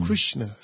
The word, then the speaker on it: Krishna: You remember, whenever we sang this bhajan before Swami, even in the later days in Prashanti Nilayam, Swami spent hours on end trying to correct the pronunciation of Krishna. And then Swami would sound so beautiful. Krishna, Swami would say.